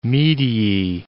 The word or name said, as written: mediae